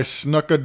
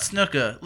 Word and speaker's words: Snuka: It's not Snuka. – It's S-N-U, not S-N-O-O.